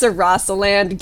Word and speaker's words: Sarasaland: Said, pronounced it as, like, Sahasraland. It's Sarasaland. Get Daisy's lore right.